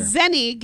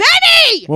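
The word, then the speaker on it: Zenni: You can the Zennia link is there. – Zenny, get the name right. Zennia. It's Zenny!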